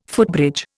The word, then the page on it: footbridge: Indian